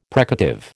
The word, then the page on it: precative: American English